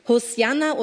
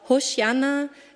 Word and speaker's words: Hosianna: Hosianna oder auch Hosianna, im Hebräischen gibt es einen Buchstaben, der nur durch einen Punkt unterscheidet, ob es als Sch oder als S ausgesprochen wird.